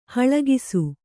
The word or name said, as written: ಹೊಳಕಿಸು